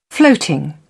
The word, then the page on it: floating: British English